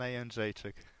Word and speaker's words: Jędrzejczyk: Jędrzejczyk. – Say her name for us. – It's Joanna Jędrzejczyk.